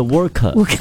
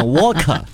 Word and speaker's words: Worker: 对，劳工是 Worker。然后他念成 Walker， Walker 就是走路，Johnny Walker 的那个 Walker。